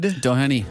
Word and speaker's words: Doheny: And I got to defer to you for the pronunciation of his name. The song is Get It Up for Love by Ned Doheny.